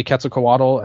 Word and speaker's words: Quetzalcoatl: Her legacy continues on through Sakaguchi and especially Okatani, who adopted the Quetzalcoatl as his finisher. – I tried to pronounce it right. I'm half. – No, no, you're good. you were fine.